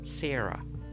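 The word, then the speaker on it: Sarah: در زبان انگلیسی این به این شکل تلفظ میشه سیرا سیرا سیرا سپلینگش S A R A H S A R A H این سیرا هستش یه سیرای دیگه هم داریم که S-A-R-A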